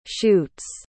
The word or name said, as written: shoots